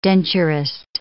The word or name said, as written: denturist